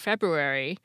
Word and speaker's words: February: If you've ever wondered why there's a silent R in the middle of the word February, you'll have the Latin februa, meaning to cleanse, to thank.